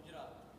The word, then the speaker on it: Gujarat: How do you say Gujarat? – Gujarat. – See, Gujarat. Not, I do it wrong.